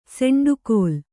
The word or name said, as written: ಸೆಂಡುಕೋಲ್